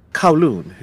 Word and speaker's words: Kowloon: How did you pronounce the name of this city? – Kowloon. It's a part of Hong Kong.